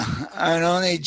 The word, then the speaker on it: Iannone: And also in the latest newsletter, you offer some congratulations to a former student of yours, Jamie Iannone, I think it's pronounced. – Yeah, Iannone, Jamie.